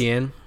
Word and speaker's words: Etienne: What is it? Eifert? I can't pronounce his name. I always pronounce Etienne. – Etienne. That's what his name is.